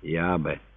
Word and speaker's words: Иабе: Имя Бога он произносил на самаритянский манер – Иабе.